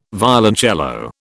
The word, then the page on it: violoncello: British English